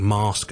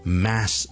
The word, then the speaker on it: mass: 咁 MASS 呢個字咧，以前咧係讀錯嘅，OK，即係我讀大學嘅時候咧，係成日讀錯嘅，去到自己英文都唔差嘅程度嘅時候咧，我都讀錯呢個字，我就將佢讀成 mask 嘅。 mas 仲有一个 a 音嚟读噶，咁但系正确嘅发音咧系一个 a 音嘅 a，即系我哋好似读 fat f a t fat，或 cat c a t cat a fat cat a，咁咧挤落去 mass 呢个字度咧就系读成 mass。